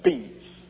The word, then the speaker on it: Beza: You know, he's Bezo was French, and the French pronounce this B-E-Z-A with a gizmo over the first E. And it's pronounced in the French, they pronounce it bees.